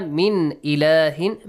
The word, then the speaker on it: مِنْ إِلَٰهٍ: وَمَا مِنْ إِلَٰهٍ. مِنْ إِلَٰهٍ, you have to pronounce the noon. إِلَٰهٍ إِلَّا, pronounce the tanween as well.